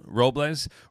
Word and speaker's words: Robles: say his name, Robles.